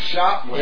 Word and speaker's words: Shotwell: With the S and the H and O-T-W-E-L-L. – Oh, Shotwell.